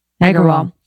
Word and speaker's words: Agrawal: Agrawal.